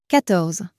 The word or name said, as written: quatorze